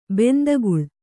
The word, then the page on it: ಬಾಂದುಕಲ್ಲು: bāndu kallu